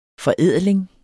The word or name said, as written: forædling